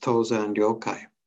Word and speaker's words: Tozan Ryokai: So the poem that is attributed to one of our Chinese ancestors named Dongshan Liangjie, or in Japanese, his name is usually pronounced Tozan Ryokai.